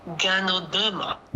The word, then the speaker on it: Ganoderma: Ganoderma。 – 纠正一下，应该念 Ganoderma。